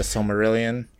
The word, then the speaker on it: Silmarillion: The Silmarillion? Is that what it's called? Am I butchering that? – Yeah, the Silmarillion.